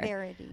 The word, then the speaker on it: Verity: Verity.